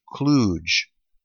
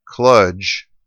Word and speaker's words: Kluge: but emails that very confidently asserted that the correct pronunciation is Kluge, as in like stooge, whereas I've always heard it Kludge, as in fudge.